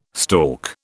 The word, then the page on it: stork: British English